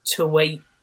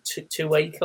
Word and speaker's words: Tuwaiq: And that was the Tuwaik... That's spelled T-U-W-A-I-Q. Tuwaik, I want to say.